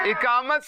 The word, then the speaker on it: اقامت: اچھا کچھ لوگ اس کو اقامت کہتے ہیں. اقامت اقامت اقامت نہیں ہوتا, درست تلفظ کیا ہے؟ – اقامت. – صحیح اس کا تلفظ ہے.